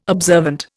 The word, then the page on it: observant: Indian